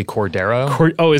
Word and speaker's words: Cordero: It's actually Cordero. – Is it Cordero? – Not a big deal, but just like pronounce it right.